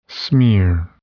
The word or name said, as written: smear